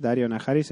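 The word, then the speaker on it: Daario Naharis: No sé si os disteis cuenta, pero yo recién caigo que en inglés Dario Naharis se pronuncia igual que Daenerys.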